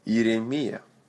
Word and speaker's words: Иеремия: Иеремия. Иеремия. Accent on the last syllable. That same sound. Мия. Мама мия.